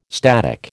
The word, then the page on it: static: American English